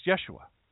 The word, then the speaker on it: Yeshua: Properly pronounced Yeshua.